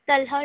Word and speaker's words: Talha: Talha Rahman, TALHARAHMAN.